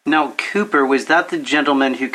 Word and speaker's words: Cowper: Also, too, with his speaking of depression, his relationship with William Cooper, looks like Cowper, if you ever look it up, but okay. It's pronounced Cooper, C-O-W-P-E-R.